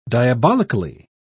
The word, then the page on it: diabolically: British English